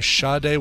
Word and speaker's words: Sade: Later years, she's gone by the name Sade with that R not getting pronounced.